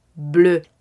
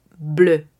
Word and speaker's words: bleu: Pasemos al color azul, bleu, bleu, b l e Tengamos cuidado con la versión anglófona, así que azul en francés es bleu. Si es el femenino, agregaremos una E al final, pero la pronunciación será enteramente la misma, bleu. Pasemos al color café. Estamos hablando del color marrón en francés, M-A-W-R-O-N.